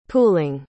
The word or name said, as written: pooling